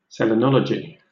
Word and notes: selenology: British English

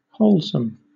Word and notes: wholesome: British English

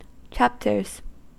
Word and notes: chapters: American English

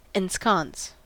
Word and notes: ensconce: American English

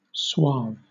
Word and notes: suave: British English

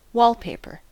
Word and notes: wallpaper: American English

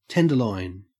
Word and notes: tenderloin: Australian English

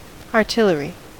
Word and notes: artillery: American English

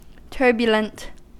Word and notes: turbulent: American English

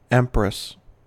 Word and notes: empress: American English

